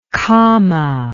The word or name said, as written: Khama